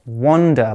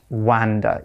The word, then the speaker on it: wander: And remember, it's pronounced wander, like with an O, not wander, even though it's written with an A.